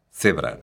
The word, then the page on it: cebra: galega